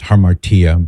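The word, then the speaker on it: hamartia: And here's what it has for harmatia. It's actually pronounced harmartia,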